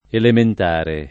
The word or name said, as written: elementare